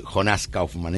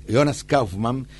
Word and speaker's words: Jonas Kaufmann: Finalmente, Jonas Kaufmann, vio que bien que pronuncie, no es Jonas Kaufmann, es Jonas Kaufmann, nacido en Múnich, así está bien,